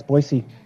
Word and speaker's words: Boise: Yeah, it's Boise. – Boise. Is it Boise or Boise? – You always know who's from town and who's from out of town, because locals say Boise, out-of-towners say Boise.